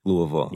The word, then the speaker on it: Louisville: Yeah, Louisville.